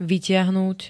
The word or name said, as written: vytiahnuť